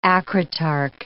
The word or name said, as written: acritarch